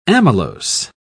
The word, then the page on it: amylose: British English